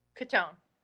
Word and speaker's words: Cottone: How do you say the last name? Cottone or Cottone? – Cottone. – Okay, Cottone. Okay.